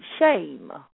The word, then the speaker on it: shem: It says S-H-E-M. But when you go and try to pronounce it, it'll be shame.